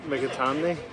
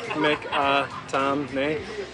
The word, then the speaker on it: Mikitamny: Yeah, so it's Jude Mikitamny. So if you break it down into like four parts and just pronounce it like Mikitamny and say it once, that's it.